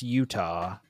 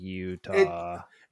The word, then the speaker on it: Eutaw: It's pronounced Utah. – It is. – It definitely doesn't look like Utah. – It's spelled E-U-T-A-W. Utah. – Right. So Utah.